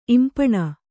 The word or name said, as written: ಇಂಪಾಣ